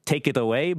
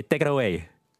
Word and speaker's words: Tekeraoi: It's spelled like this, T-E-K-E-R-A-O-I, but it's not pronounced that way, as you can imagine. It sounds kind of like take it away, but you say take it away.